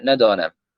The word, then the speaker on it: ندانم: كيف تنطق؟ – ندانم هكذا. – ندانم؟ – نعم.